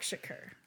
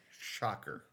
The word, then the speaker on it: Shakur: Tupac Shocker. – Tupac Shocker. Ugh. Don't like that.